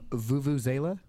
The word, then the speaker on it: vuvuzela: How you pronounce that? I've always wanted to know. – Vuvuzela? – Vuvuzela. – As in vuvuzela.